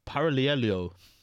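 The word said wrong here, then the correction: Paralluelo: Suck, man. I know I suck. Help me out here, bro. Honestly, my pronunciations are getting worse and worse, fam. – Parayuelo.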